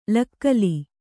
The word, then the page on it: ಲಕ್ಕಲಿ: lakkali